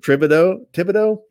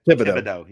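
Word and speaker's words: Thibodeaux: I'm going to make an effort to really pronounce his name correctly. Kayvon Thibodeau. – Yeah. – Thibodeau.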